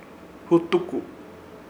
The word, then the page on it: Hutuko: Ishir; Chamacoco